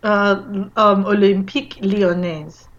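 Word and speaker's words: Olympique Lyonnais: Olympique Lyonnaise.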